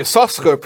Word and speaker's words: Yisachar: In Yisachar, it has two sins, but one you pronounce, the other one you don't pronounce, because one represents the Torah that's revealed, the more practical, the legalistic or the exoteric, and the sin that you don't pronounce, which is the spiritual teachings. Anyway, but B'nai Yisachar pronounced it Yisachar.